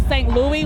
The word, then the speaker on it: St. Louis: When someone pronounces St. Louis like St. Louis, what's your first response? – Cringe.